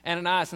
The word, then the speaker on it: Ananias: When I was a kid, everybody called them Annas and Sapphire. So I mispronounced their name for like 30 years. In Acts chapter 5, Ananias and Sapphira, we learned that Barnabas wrote that check.